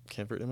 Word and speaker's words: Camp Verde: Camp Verde, am I saying that right? – Yeah.